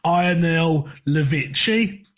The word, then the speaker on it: Ionel Levici: Looking at this, it looks like it's Ionel Levici. I'm going to go with. – That's the one. You pronounce it.